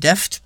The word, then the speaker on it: Daft: Also der Daft Nein, Deft. Wie wird das ausgesprochen? – Deft. Deft. Deft.